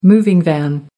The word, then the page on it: moving van: American English